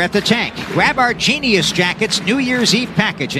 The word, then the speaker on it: Jeanius: Just for information on the radio audience, Genius is spelled J-E-A-N-I-U-S jackets.